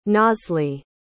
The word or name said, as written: nazli